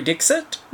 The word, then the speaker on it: Dixit: Now, this first week we had to do a film starring Madhuri Dixit. – I think the pronunciation of her surname, I think the X is more of a sh sound, but I don't really want to call her Madhuri Dixit for the rest of this podcast.